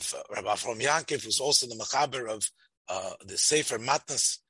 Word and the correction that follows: Minchas Avraham: Am I saying it right? Matnas Avraham. – Minchas Avraham. The Minchas Avraham.